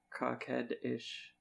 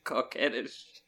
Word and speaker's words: coquettish: Now that I've learned what cockhead-ish and colt mean. – Say it right. Say it right. – Coquettish. – Oh my god, not only is he vowel blind, he's French blind too.